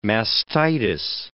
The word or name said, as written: mastitis